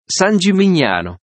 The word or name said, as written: San Gimignano